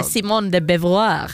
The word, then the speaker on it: Simone de Beauvoir: Att det är Simone de Beauvoir.